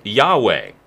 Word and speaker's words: Yahweh: There is the word Yahweh, if you'll permit that pronunciation.